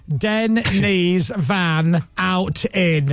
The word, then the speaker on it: Denise Van Outen: That's how you pronounce Denise Van Outen, is Den, knees, van, out, in.